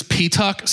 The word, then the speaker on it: Petuck: My last name is Petuck, so I've gotten everything from Peacock to Pizza Hut to...